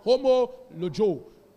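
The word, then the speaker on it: homologeo: Confession is practically from a Greek word called homologio. Everybody say homologio. Say it loud. Say homo. That's H-O-M-O, logio. It's pronounced L-O-G-E-O.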